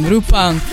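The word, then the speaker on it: Rupang (루팡): You pronounce it in Korean, Rupang.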